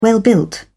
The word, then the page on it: well built: British English